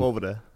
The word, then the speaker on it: Sepulveda: Sepulveda.